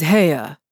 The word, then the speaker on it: Haigha: His name is Haigha. He pronounced it so as to rhyme with Maya.